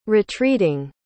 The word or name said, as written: retreating